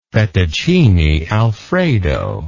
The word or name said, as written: fettuccine alfredo